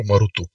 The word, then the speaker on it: Marduk: Marduk se původně prý vyslovoval ne jako Marduk, ale jako Marutuk.